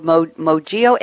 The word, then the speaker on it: Mojio: And I'm going to turn to our second panelist, CEO of Mojio, M-O-J-I-O. Am I pronouncing it right? Yes? – That's correct. Mojio. – Oh, good. Mojio. I had the accent wrong, but the pronunciation right.